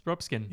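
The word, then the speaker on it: Ruxpin: Rupskin.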